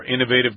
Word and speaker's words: innovative: I've also heard this word pronounced as innovative. That's probably the British pronunciation. – It's only British. – Only British, yes. Americans don't say it that way. We say innovative with the accent on the first syllable.